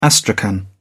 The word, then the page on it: astrakhan: British English